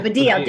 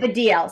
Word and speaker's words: Baddiel: Jews Don't Count by David Baddiel. Thank you for... – I have to say it's Baddiel. Allison. – Baddiel. Oh, sorry. Baddiel. David Baddiel. Sorry. It's my American accent that's watching everything.